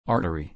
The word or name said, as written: artery